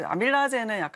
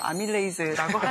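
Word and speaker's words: amylase: 네, 이제 아밀라제는 약간 좀 구식 발음이고 아밀레이즈라고 하는데, 아밀레이즈.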